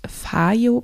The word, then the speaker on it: Fayo: Und der Gutscheincode für einen 10% Gutschein, das ist relativ viel bei diesem Betrag, ähm, der heißt FAYO Podcast 10. Das schreibt man Fayo, F-A-Y-O, Podcast in einem Wort und dann die Zahl 10, alles klein geschrieben.